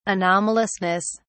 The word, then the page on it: anomalousness: British English